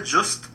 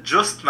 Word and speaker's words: Justh: And secondly मैं अपने आपके लिसनर्स को ये बोलना चाहता हूँ कि मेरा नाम है जस्ट. आपको नाम याद करने में हो सकता है एक आध मिनट का एफर्ट लगे, लेकिन आप करिए एफर्ट. और जस्ट नाम है और उसकी स्पेलिंग है JUSTH.